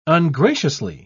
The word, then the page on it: ungraciously: British English